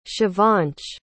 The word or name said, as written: shivansh